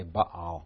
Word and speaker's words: Baal: which in our English Bible is written B-A-A-L. The reason there are two A's there is that there's really a stop in between them. We usually say Baal.